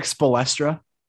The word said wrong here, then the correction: Spoelstra: did i say his name wrong the, the e's before the e's before the l it's Spoelstra. Spolstra.